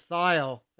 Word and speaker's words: Thile: Dead Leaves in the Cool Ground by Chris Thiel.